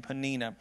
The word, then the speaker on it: Peninnah: And the name of the other was Peninnah. That's how you pronounce that name. Peninnah had children, but it says Peninnah had children, but Hannah had no children.